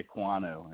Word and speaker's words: Ekwonu: It goes by Icky, you know, Icky Ekwonu is how you say it.